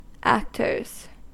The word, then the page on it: actors: American English